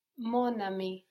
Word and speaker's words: mon ami: Now, just like the E on the end of monde makes us pronounce that D, this can also happen across word boundaries in French. We get mon, my, ami, friend, but putting them together, mon ami.